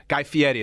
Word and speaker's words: Guy Fieri: Guy Fieri, as he pronounces it.